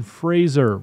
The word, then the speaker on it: Fraser: Brendan Fraser rhymes with razor.